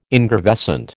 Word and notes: ingravescent: American English

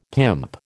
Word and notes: pimp: American English

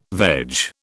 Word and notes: veg: British English

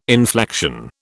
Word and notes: inflection: British English

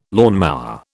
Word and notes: lawnmower: British English

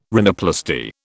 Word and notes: rhinoplasty: British English

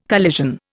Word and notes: collision: Indian

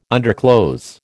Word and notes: underclothes: American English

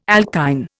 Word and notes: alkyne: Indian

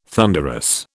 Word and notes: thunderous: British English

